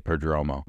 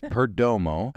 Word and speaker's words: Perdomo: I got to say his name right because it's so easy to say Perdomo, but it's Perdomo. Geraldo, I checked, even though that, that G is not a J in Spanish.